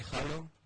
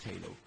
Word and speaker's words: Halo: Conocido aquí Halo, pronunciado en inglés Halo.